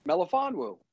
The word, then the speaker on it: Melifonwu: How you say? – Melifonwu. That's his last name.